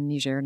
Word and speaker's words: Niger: I was teaching in Niger, Niger's the English pronunciation, just north of Nigeria.